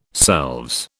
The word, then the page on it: selves: British English